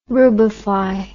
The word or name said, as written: rubefy